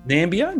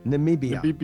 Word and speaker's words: Namibia: What is that how you say it? Nambia? Namibia? What are they called? – Namibia.